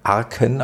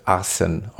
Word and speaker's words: Arcen: Äh, spricht man den Arsen aus? Ich glaube ja, ne? Mhm, genau. Er wird ja. geschrieben A-R-C-E-N. Man könnte auch meinen Arken, aber ich glaube, er wird Arsen ausgesprochen. Ist Arsen.